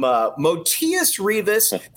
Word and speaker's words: Motiejus: Speaking of a guy that's got the red carpet rolled out, Arizona just got a commit right now from Motiejus. Thanks to the U of A for sending me that pronunciation right there.